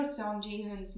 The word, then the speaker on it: Song Ji-hoon: You know Seong Ji-hun's. I'm going to say his name like that. Sorry, it's wrong. You know Song Ji-hoon's mom?